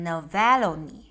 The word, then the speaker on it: Navalny: 亚历克谢· 纳维尔尼，我们会念成 Navalny。